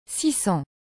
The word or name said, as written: 600